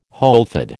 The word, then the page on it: wholefood: American English